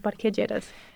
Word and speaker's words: Parque Lleras: I went as a tourist and I went to this park, Parque Lleras. Is that how you say it? – Yeah, el Parque Lleras.